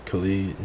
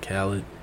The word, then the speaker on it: Khalid: Khalid, not Khaled. But I think it's Khalid.